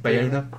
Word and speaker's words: Bayona: Bayona.